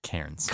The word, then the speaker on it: Cairns: Where are we? Carnes? – Cairns. – Carnes. – Cairns or Cairns is how they say it.